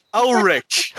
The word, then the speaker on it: Ulrich: com o Fernando Urich, sensacional. – É Urich. – Urich, Ulrich. Fernando Ulrich.